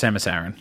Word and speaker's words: Samus Aran: Jose, it's pronounced Samus Aran.